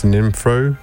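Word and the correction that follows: Sinephro: No, that was not how you say it, is it? How do you say it, Sam? – Sinefro. – Sinefro.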